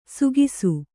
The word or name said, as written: ಸುಖಿಸು